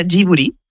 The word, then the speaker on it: Ghibli: En japonés se pronuncia Ghibli. Uh-huh. Porque ellos no tienen el sonido de la L, pero es una historia bastante graciosa porque el nombre lo elige Hayao Miyazaki y lo elige por la palabra japonés.